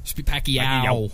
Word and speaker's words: Pacquiao: Pacquiao. – Yeah. Pacquiao.